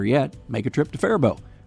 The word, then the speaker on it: Faribault: It's pronounced Faribault. It's spelt Faribault.